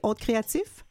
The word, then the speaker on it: Haute Créative: C'est comme ça que vous le prononcez, Haute Créative? – Oui, c'est ça.